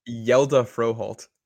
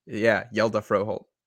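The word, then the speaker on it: Hjalte Frøholdt: Yodney Kajust, Jalte Froholt, and Jarrett Stidham. – Yelda Froholt. I had to learn that pronunciation. – Yelda? – Yelda Froholt.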